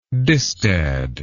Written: distad

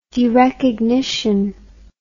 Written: derecognition

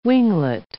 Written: winglet

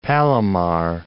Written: Palomar Mountain

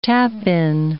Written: tap-in

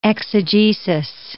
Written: exegesis

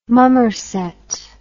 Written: Mummerset

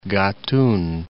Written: Gatun Lake

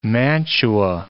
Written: mantua

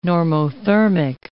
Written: normothermic

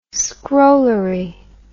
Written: scrollery

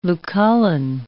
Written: Lucullan

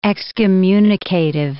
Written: excommunicative

